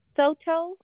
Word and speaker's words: Soto: Soto, SOTO, Lucena, LUCENA.